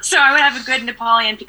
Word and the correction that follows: Neapolitan: or how do I say it? – Neapolitan. – Neapolitan pizza.